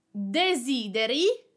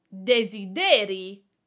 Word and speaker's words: desideri: Desideri, you desire, the accent is on the first E, desideri, and desideri, desideri, the accent is on the last E. It means desires.